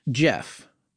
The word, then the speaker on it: Gef: And what we've been hearing from a lot of listeners about is Jeff, which is spelled G-E-F, right? – Yes, yeah.